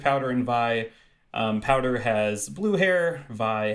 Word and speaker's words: Vi: It is pronounced Vi because it is short for violence, is something that I learned at some point.